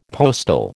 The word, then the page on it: postal: American English